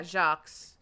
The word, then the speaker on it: Jacques: Yeah, Jacques.